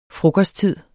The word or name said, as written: frokosttid